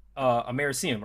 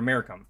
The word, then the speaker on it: americium: And one of them is americium or americum. Remember how. Yeah, that's, I've always said it americium, but that's, it doesn't matter how you pronounce it, but it's named after the Americas.